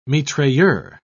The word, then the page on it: mitrailleur: British English